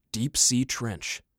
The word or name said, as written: Deep-sea Trench